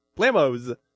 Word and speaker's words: Blamos: Blamos like toes? – Yeah. – Blamos like jam toes? – I'm sure they appreciate that.